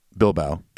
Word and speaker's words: Bilbao: Then we had a brutal flight to Balboa. – Rocky Balboa, Spain. – Or Bilbao. – Bilbao. – How do you say it? – Bilbao.